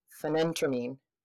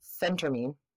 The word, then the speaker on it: phentermine: Fenfluramine and phenentermine. Nope. Phentermine.